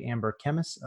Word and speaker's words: Amber Kemmes: You're listening to the Iffy Market Podcast, and we have on today Amber Kemmes of Smartbug Media.